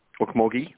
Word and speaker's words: Okmulgee: Okmulgee, you're right, it is a hard G.